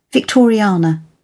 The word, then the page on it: victoriana: British English